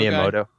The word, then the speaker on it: Miyamoto: The Nintendo Miyamoto.